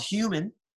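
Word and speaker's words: Huemen: I have a band called Human. Mm-hmm. Spelled like Hue as in color, H-U-E. That's so confusing. But so it's H-U-E-M-E-N, yep.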